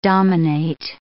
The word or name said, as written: dominate